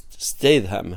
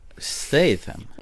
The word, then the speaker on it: Statham: Eller nu heter han inte Statham, han heter eh, Statham heter han egentligen om man ska se honom uttala det. Statham? Ja, jag har alltid sagt Statham, men det är ju Statham ska det vara. – Inte med the ljud i slutet då.